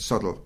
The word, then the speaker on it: Southwell: But you had some landlords that were particularly interested in the Palatines, and one of those was Thomas Suttle in Rakeel, or Southwell. The family actually pronounced it Suttle.